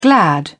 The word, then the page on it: glad: British English